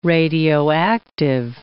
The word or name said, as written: radioactive